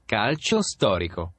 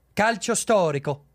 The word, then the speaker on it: Calcio storico: C-A-L-I-C-O, right? – Calcio storico. – Calcio storico.